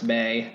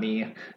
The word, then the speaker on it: May: You got, it's going to be May, and yes, it's pronounced May, not me.